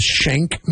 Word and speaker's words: Schenck: It's called the Shank Mansion. – Don't get shanked. – It sounds like the prison weapon, but it's spelt differently. It's like S-C-H-E-N-C-K Mansion.